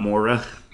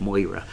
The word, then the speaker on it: Moira: It's not Moira. – Moira. – Moi. – Yes, moi. – Moi. Like, get your inner judge. – Oh, that's what that is. Yeah, Moira.